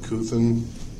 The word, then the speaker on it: Couthen: John Cuthin, C-O-U-T-H-E-N.